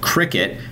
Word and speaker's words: Cricut: We're going to chat with a company called Cricut. It's spelled C-R-I-C-U-T, and their cutting machine called the Cricut Maker 3.